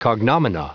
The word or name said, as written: cognomina